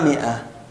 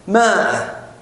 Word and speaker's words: مئة: شوف مشكلتنا في مئة أن العرب تنطقها مئة وتكتبها بهذا الشكل. تكتبها بهذا الشكل أليس كذلك؟ ولكنها تنطقها ماذا؟ مئة. المشكلة جانا ناس ينطقها ماء، والمشكلة الكبرى في هذا إنه ينطقها ماء في المقامات، مقامات يعني مقامات الثقافية، يعني هو يلقي كلمة، هو يعني هو يروح السوق يقول مية، بعدين يجي يلقي كلمة يحط النظارة على رأس أنفه بهذا الشكل، وبعدين يقول وقد كلف المشروع مائة.